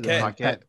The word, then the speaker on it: Paquette: Is it Paquette? – Okay.